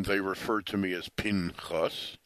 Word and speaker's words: Pinchas: And even though I call it Pinchas, the real pronunciation of the name is P-Nechas, although only pure Israelis will say that on a person's name.